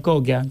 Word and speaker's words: Cocchia: Es C, o sea, el original es COCCHIA. Es con doble C, digamos. Y entonces es Cochia.